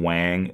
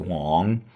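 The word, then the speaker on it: Wang (王): This is one of the most common Chinese last names, so if you ever meet a Chinese person, don't call them Wang, it's pronounced Wang.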